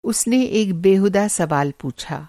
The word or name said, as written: बेहूदा